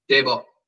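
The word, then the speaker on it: Daboll: Dable, yeah.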